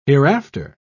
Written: hereafter

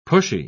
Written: pushy

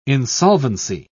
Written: insolvency

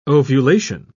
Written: ovulation

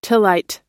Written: tillite

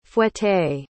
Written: fouetté

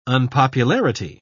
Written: unpopularity

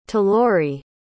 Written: talori

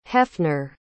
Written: hefner

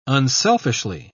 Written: unselfishly

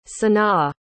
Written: sanaa

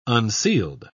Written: unsealed

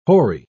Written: hoary

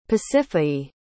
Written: pasiphaë

